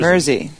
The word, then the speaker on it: Mersey: I guess it's Mersey, like Jersey. – Mersey, yeah, Mersey, or Quincy.